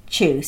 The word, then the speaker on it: Chiu: You can find out more about Hot Chew, that's spelled Chew, C-H-I-U, on his Facebook page, Hot Chew Foods.